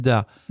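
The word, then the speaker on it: d'Arz: C'est drôle parce que moi, ça m'avait surpris quand j'étais allé aux Moines, parce que l'île d'Ar est à côté. L'île d'Ar, je ne sais pas si on dit ça. – D'Ar. – On ne prononce pas le Z.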